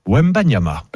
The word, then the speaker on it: Wembanyama: Son nom, on va devoir apprendre à bien le prononcer parce qu'on va le prononcer pendant quelques années, c'est Victor Wembanyama.